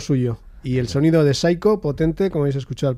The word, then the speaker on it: Xaiko: Las canciones de Saiko, que se escribe no como sería preceptivo, así con ese toque en inglés, con la P, la S, la Y, C-H-O, Saiko, sino directamente en euskera, X-A-I-K-O.